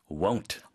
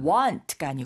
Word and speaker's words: won't: Won't. – Won't. – Won't go for it. – 네. Won't go for it. 그러니까 want가 아니고 won't 해서 입이 오므러졌다가 다시 와. 커졌다가 또 오므라지는 겁니다.